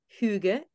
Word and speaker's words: Hygge: And you mentioned Hygge.